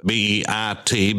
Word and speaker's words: Beit: Uh, Beit or Beth, B E T H or B E I T, Beit, the modern pronunciation, is the word for house, the house of assembly.